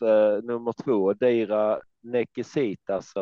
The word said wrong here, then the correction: Necessitas: eller eh, någonting ditåt. – Necessitas. – Necessitas.